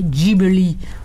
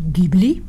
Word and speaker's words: Ghibli: Voilà, donc il a fondé un studio qui s'appelle alors on peut le prononcer Ghibli ou Ghibli.